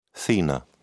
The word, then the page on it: thenar: British English